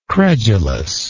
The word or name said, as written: credulousness